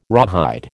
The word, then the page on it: rawhide: American English